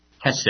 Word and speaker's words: hesed: And just to look quickly at that word, unchanging love, that is the Hebrew word hesed. You got to really get some saliva in your back of your mouth to pronounce that properly.